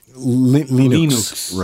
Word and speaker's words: Linux: Linux.